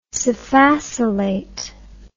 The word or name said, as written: sphacelate